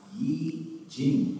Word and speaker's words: I Ching: Their performance selection is I Ching, pronounced Yi Jing, okay, by Duane Rice.